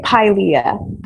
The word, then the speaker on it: Pilea: We say Pilea. So like, think pie. That helps.